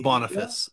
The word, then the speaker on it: Boniface: Boniface. It's pronounced Boniface. – Boniface, by the way.